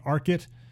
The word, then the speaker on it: Archit: You can learn all about Boris and his company, Arkit. It's spelled A-R-C-H-I-T, so like ARC for like architect, and IT for information technology.